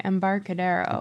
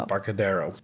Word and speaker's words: Embarcadero: Okay, Embarcadero. – Embarcadero.